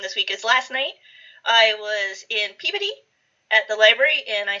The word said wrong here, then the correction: Peabody: Sorry, you were in puberty? – Peabody. – Peabody. – Peabody. – Could you spell that? – It's been a long time since I was in puberty. – Could you spell that? Because I can't. It still sounds like puberty. – P-E-A-B-O-D-Y. Peabody. – Oh, Peabody.